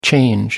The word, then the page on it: change over: American English